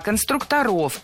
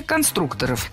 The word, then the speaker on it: конструкторов: Конструктора, конструкторов, это неверно. В слове «конструктор» ударение должно сохраняться на втором слоге, на «у». Поэтому конструкторы, конструкторов.